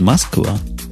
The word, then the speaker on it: Москва: Бобук правильно говорит Москва.